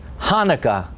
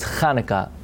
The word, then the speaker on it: Hanukkah: For example, it's Hanukkah. So you say, no, it's Hanukkah. That sound doesn't appear, is not present in English.